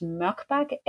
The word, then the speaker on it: Morkbak: And my last name is Morkbak, M-O-R-K-B-A-K.